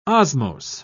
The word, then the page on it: osmose: British English